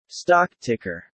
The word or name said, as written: stock ticker